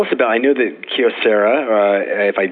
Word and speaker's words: Kyocera: Is it Kyocera or Kyocera? – If you're Japanese, you say Kyocera, Kyo being one syllable. So try to say Kyocera, and you've got it.